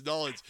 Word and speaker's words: New Orleans: You were born in Nollins, as people know as New Orleans. If you don't, now you know that. Now you know the pronunciation is Nollins.